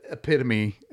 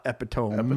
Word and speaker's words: epitome: Sorry, but epitome is said epitome and not epitome. – Epitome. – Does have four syllables.